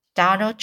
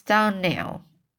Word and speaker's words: Donnell: Clear, uh, sorry, this is Clario, Clary, Clary, Clary, so Clary, Clary, uh, Clary, Miro Carissa Carisomiro told me that she pronounced our name Donald. Now, Miss Shirley, the correct pronunciation of our name is Donnell, accent of the last syllable.